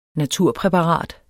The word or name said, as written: naturpræparat